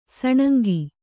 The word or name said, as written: ಸೂನಂಗಿ